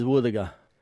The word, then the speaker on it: wuthuga: Our local Mulgana word today is woothaga. What is a woothaga? Woothaga is a dugong. You can use woothaga in this sentence. Ngada gamanu woothaga yoonga. I cooked dugong yesterday. Woothaga is spelled W-U-T-H-U-G-A. Woothaga.